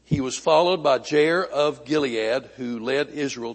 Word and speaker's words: Jair: All right, Jair, like hair, Jair. Now, I was hoping that my resident Hebrew scholar would be here today to tell me if I'm pronouncing that correctly. It's right in English, but I don't know if it's right in Hebrew.